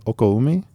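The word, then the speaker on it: okoumé: It's O-K-O-U-M-E, Okoumi wood.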